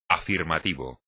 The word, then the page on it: afirmativo: espagnol